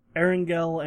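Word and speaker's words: Erangel: I'm never sure how to pronounce that. – Erengel and Miramar. – Erengel, okay. Yeah,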